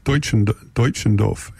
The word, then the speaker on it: Deutschendorf: Bob Middleton tells me how to pronounce it. It's Deutschendorf.